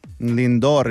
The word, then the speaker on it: Lindor: Lindor is how I say it.